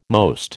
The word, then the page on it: most: American English